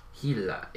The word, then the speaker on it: Gila: Gila. It's Spanish. – Oh. Always. Always with the Spanish. – Yeah. – Changing up the pronunciation of our letters.